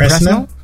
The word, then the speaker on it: Presnell: How do you say that last name? P-R-E-S-N-E-L-L. – Presnell. – Is it Presnell? – Presnell.